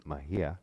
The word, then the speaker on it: Mejia: It's Julia Mejia.